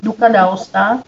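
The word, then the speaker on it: Duca d'Aosta: Duka d'Aosta.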